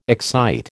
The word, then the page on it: excite: American English